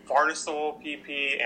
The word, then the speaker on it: farnesyl: Another thing that statins block our body's ability to produce is something called farcinyl-PP. And this eventually gets turned into a molecule called dolichol. And how would you pronounce that? – Farnesyl-PP and dolichol. I think, yeah, dolichol. – Dolichol? Right. Okay, yeah, because it's a weird-looking word.